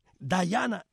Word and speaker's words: Diana: Pero en inglés, Diana se pronuncia como su nombre. Dayana.